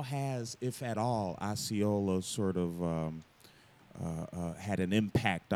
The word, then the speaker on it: Osceola: I don't know how to pronounce it. – Osceola. – Osceola, okay.